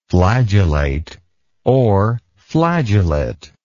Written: flagellatory

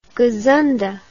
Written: gazunda

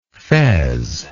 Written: fezzy

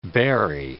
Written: bury

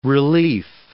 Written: relief

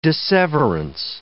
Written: disseverance

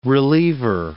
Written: reliever

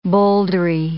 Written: bouldery

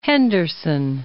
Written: Henderson

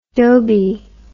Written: dhobi

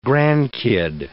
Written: grandkid

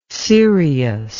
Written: seirios

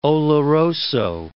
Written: oloroso

